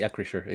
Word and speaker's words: Acrisure: Sí, Acrisure, exacto.